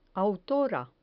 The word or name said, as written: autora